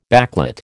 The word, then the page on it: backlit: American English